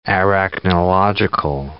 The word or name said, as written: arachnological